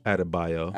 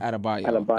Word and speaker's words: Adebayo: Adebayo. – Adebayo.